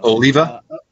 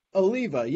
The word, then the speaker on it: Oliva: Like, looking I actually looked at someone brought up Jared Olivia. I might be saying the last name wrong. – Oliva? – Oliva, yeah.